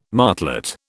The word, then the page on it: martlet: British English